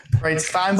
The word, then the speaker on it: Breitstein: Breitstein, but we'll take it. Kind of like Bierstein, Einstein, or any of the above.